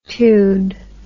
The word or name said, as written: tude